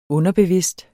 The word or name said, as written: underbevidst